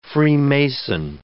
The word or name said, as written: freemason